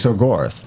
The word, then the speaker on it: Exogorth: actually a space slug called the Exogirth. Is that how you pronounce it? – Exogirth? – Exogirth? – It's Gorth? Like Gorth, I guess? – Exogorth.